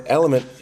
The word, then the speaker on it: LMNT: So he kept saying, I put Element in my water, but I couldn't find it when I searched it. It's because it's LMNT. It's spelled L, the letter LMNT.